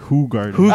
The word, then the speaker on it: Hoegaarden: Just for reference, actually, and I hate to kill this for you, but it's actually pronounced Hoegaarden.